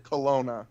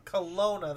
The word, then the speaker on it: Kelowna: It's hard for me to pronounce the name of the city that you were in. Is it Kelowna? – Kelowna, that's it.